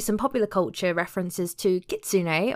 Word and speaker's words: Kitsune: Yeah, I just realised I'm pronouncing it wrong. It's Kitsune. It's not how I was saying it before, so it's Kitsune.